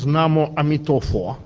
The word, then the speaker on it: Namo Amitofo: The proper pronunciation of this mantra in Chinese is Namo Amitofo.